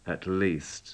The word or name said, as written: at least